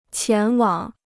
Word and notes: 前往: qián wǎng

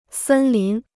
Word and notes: 森林: sēn lín

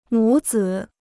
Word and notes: 母子: mǔ zǐ

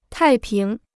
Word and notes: 太平: tài píng

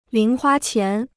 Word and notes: 零花钱: líng huā qián

